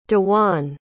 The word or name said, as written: dejuan